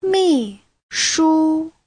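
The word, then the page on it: 秘书: mi4shu1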